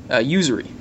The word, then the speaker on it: usury: Usury. – Yeah. – Oh, usury. I'm sorry. Yeah. – My pronunciations aren't the best.